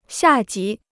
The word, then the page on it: 下级: xià jí